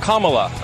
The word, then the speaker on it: Kamala: Her name is pronounced comma, like the punctuation mark, look, Kamala, okay? – Okay.